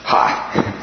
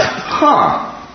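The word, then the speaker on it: ha (ἡ): The thing it's got in front of it is pronounced ha. Not huh.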